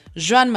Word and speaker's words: Joan: Joan Manuel Serrat, o Juan Manuel Serrat, depende cómo lo pronunciemos, si en catalán o en castellano.